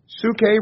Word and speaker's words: psuche: I'll spell that for you if you like. It's P-S-U-C-H-E. It's pronounced psuche.